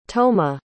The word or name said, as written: toma